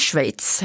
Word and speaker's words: Schweiz: Vi ska till Schweiz. Ja. Det är 2 spelningar, 2 festivaler där. – Och du säger det helt perfekt. – Schweiz, det är inte helt lätt. Ja. Ett ord med många uttal.